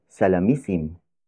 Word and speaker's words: salamisim: This lesson is brought to you by the Tagalog word salamisim. Salamisim. Salamisim. Salamisim is spelled as S-A-L-A-M-I-S-I-M.